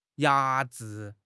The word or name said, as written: 压制